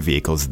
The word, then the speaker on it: vehicles: The pronunciation is vehicles, not vehicles, but vehicles. – Right. The H is silent. You just have to sort of smooth out that sound, vehicles.